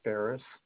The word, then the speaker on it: Ferriss: My name is Scott Ferris. Last name is spelled F-E-R-R-I-S-S.